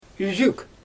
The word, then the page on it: yzyk: Xyzyl